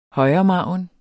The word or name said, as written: højremargin